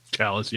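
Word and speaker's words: Challis: Dr. Daniel, is it Chalice? – Chalice, yeah, I think is how they say it. Chalice? Chalice? – Yeah, I think they said Chalice in the movie.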